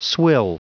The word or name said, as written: swill